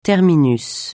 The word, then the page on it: terminus: francais